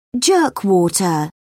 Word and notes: jerkwater: British English

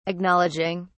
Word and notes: acknowledging: British English

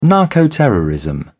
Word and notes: narcoterrorism: British English

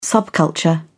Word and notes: subculture: British English

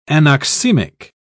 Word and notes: anoxemic: British English

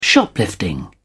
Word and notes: shoplifting: British English

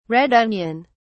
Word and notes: red onion: British English

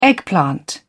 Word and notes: eggplant: British English

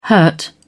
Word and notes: hurt: British English

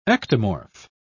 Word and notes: ectomorph: British English

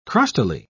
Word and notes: crustily: British English

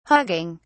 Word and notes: hugging: British English

